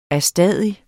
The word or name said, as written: ADSTADIGT